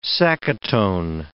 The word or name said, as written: sacaton